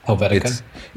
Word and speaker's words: Helvetica: Helvetica, whatever it's called. – Helvetica.